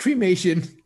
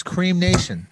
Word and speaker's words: cremation: It's pronounced cremation. – Cremation. Right? – No, it's cream nation. That's how you say it. Cream nation. It's cream nation.